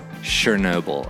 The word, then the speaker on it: Chernobyl: Chernobyl, okay.